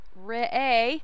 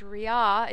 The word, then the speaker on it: Re'eh: This week's Torah portion is Re'eh, or sometimes it's pronounced Re'eh, or sometimes it's pronounced Re'ah. It depends on who you're listening to. There are very many ways of pronouncing this.